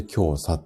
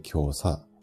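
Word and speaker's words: 胸鎖: で、えっ、ー、と、これで今日さって読みます。胸の鎖って書いて今日さ。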